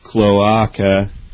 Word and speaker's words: cloaca: Black Belt Duck says cloaca is pronounced cloaca. Trust me, newts know. Isn't that how I've been pronouncing it? Or did I say cloaca? – I think she's saying it's cloaca. – Really? – I don't believe her. – MWN line says cloaca is pronounced cloaca. Oh. And then Moop says cloaca is pronounced cloaca, by the way.